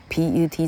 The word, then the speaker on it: put: 因为 a a e r r p u t 应该念 put，但是 p u t 这个字刚好是英文字里头的一个非常特殊的 a a a 的动词，它的发音甚至不是按照一般的发音规则，它要特别的去记它的破音字，叫做 put p u t。好，如果念。 P 如果念 putt 的话，好像变成是高尔夫球的术语哈，叫做是 putt 还是 putt 哈，老客忘了念啊的音是个高尔夫球的术语。